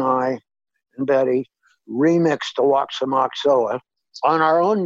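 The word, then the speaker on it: Aoxomoxoa: Oh, Oxomoxoa. – Oxomoxoa.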